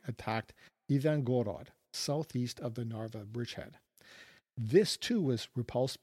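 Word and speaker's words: Narva (Нарва): At Narva on that map looks like Hapaba in Roman letters, so H-A-P-B-A, but in Cyrillic it's pronounced Narva.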